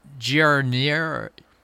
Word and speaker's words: Giarnieri: Okay, so Johnny Giarnieri. I have to give it a hard G because it's G-I.